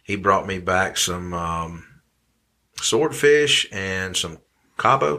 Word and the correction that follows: Cobia: Am I saying that right? Some kind of fish. I don't remember. Said it. Call it the ribeye of the sea. – Oh, okay. Cobia.